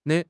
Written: ne5

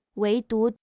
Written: 唯独

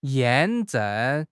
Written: 验证

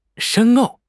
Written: 深奥

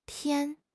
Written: pian1

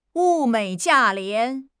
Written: 物美价廉

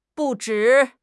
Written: 不止

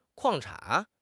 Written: 矿产

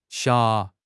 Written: 霞

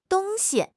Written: dong1 xi1

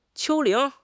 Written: 丘陵

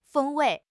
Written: 风味

